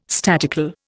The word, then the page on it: statical: Indian